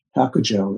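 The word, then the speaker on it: Hyakujō: So Baizhang, the current Baizhang in the story, Hakujo is how you say his name in Japanese, great master, said, greatly cultivated people are not blind to cause and effect.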